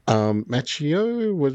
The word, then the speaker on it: Macchio: Ralph Macchio, is how you pronounce his last name, who was the original Karate Kid, is in this one with Jackie Chan.